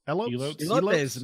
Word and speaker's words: Elotes: Elotes? – Elotes, man.